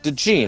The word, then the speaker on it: DeJean: DeJean, the Iowa safety slash corner. Right, DeJean, yeah.